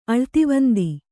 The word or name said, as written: ಅಳ್ತಿವಂದಿ